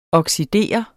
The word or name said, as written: oxidere